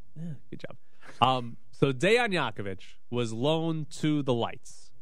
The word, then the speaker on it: Jakovic: Jakovic. On the end. – Could you put an H then? – No. That's how it's spelled.